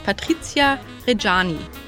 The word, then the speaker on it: Patrizia Reggiani: In diesem Kontext ging er eines Tages auf eine Party, wo er eine gleichaltrige junge Frau kennenlernte, nämlich Patricia Reggiani.